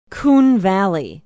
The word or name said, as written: coon valley